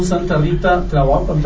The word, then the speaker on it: Santa Rita Tlahuapan: recordar eh, tu trabajo, Jorge, con Santa Rita Tlahuapan también.